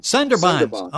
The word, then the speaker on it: Sundarbans: Sundarbans, am I saying that correctly? – Sundarbans. – Okay, I got the accent in the wrong place.